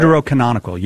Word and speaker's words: deuterocanonical: Was the – as you guys call them, Deuterochronicle – I think that's how you say it. – Deuterocanonical, yes.